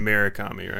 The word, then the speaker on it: Murakami: Whatever album Hurakami, is it Hurakami? Am I pronouncing that right? Is doing visuals on. – Murakami, right? – Murakami, yeah.